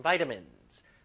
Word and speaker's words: vitamins: be rich in vitamins 발음할 때 비타민이 아니라 vitamins. 물론 영국 발음은 비타민입니다.